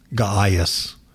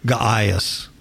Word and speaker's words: Gaius: so I'm gonna call him Gaius. I'm going to use the long A. Is that proper English, Ms. Kapow? – It's short A. – Short A? Gaius.